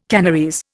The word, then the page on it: Kanarese: Indian